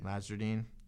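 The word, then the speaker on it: Nasreddine: Elaine Nazardine. How do you say that? – Elaine Nazardine. – Nazardine, replacement.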